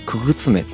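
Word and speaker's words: 傀儡女: うん、遊び目とか、まあ、遊女と書いて遊び目とかあとは傀儡の女と書いてくぐつめと呼ばれるそうなんですよ。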